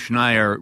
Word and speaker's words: Schneier: Bruce Schneier rhymes with, what did you say? – Frequent flyer.